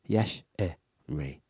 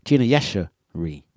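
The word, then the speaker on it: Yashere: It's Yas Hooray. Yas Hay. For the life of me, I was pronouncing her name correctly, as I always have, and all of a sudden, I can no longer pronounce her name. Gina Yash. Hey. Here. Here, Gina Yasha here. There you go. Yash-e-re. Gina Yasha-re. There we go.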